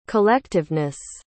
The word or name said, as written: collectiveness